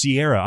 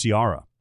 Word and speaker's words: Ciara: it's it's pronounced Sierra. I always thought it was Ciara, I did too.